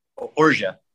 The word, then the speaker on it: orgeat: O-R-G-E-A-T? – Yeah. – How are you? – Orgia. – Like, Orgia. – Orgia. – I saw it written down like Zsa Zsa Gabor, like like that. Yeah. So Orgia.